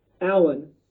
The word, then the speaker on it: Alan: My website is James Alan Sturtevant. And Alan is spelled kind of interesting. It's A-L-A-N. Sturtevant, my last name, is spelled S-T-U-R.